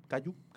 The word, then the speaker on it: kaiju: sobre este kaiju Kaiju, Kanju. – Kaiju. Kaiju. Perdón, siempre lo digo mal.